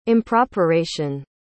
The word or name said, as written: improperation